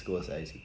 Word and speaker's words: Scorsese: How did you pronounce that? – Scorsese, yeah. – With a T? – Maybe I'm tripping about a T in there. – It's Scorsese. – Yeah, wow, that's, yeah, okay, there you go.